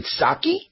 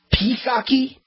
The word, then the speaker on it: Psaki: Is it Psaki? Psaki? It's P-S-A-K-I. Psaki? Jen Psaki.